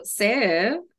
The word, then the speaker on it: sev: Well, then you mentioned something called Sev. – Oh yeah. So Sev is,